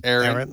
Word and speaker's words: Erin: But it's pronounced how? How is it pronounced? – Aaron. – Aaron. E-R-I-N.